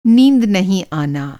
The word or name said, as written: नींद